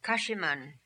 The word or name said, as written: Kashiman